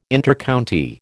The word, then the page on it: Intercounty: American English